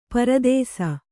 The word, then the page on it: ಪರದೇಸಿ: Kannada